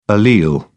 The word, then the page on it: allele: British English